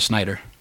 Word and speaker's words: Snyder: Or, you know, we just have a grievance with it, like a certain, you know, movie by a certain Snyder. – Yes. – Whose name rhymes with? – Mac. Miter. – There we go.